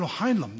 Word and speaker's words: Heinlein: It's a science fiction author, Robert Heinlein. Am I pronouncing it right? Who said that? No, Heinlein. No, H-I-E-L-A-I-N.